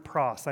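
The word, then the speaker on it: praus: And so this Greek word that we translate to our English word meek is the word pros. I may say it wrong, but that's P-R-A-U-S.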